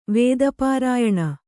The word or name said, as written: ವೇದಪಾರಾಯಣ